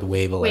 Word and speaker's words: Wavell: We're going to go with Wavell as the pronunciation, yeah.